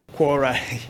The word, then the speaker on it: Quora: And the last one, exploring Quora. Have I pronounced that? – Quora. Yeah, Quora.